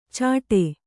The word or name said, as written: ಛಟೆ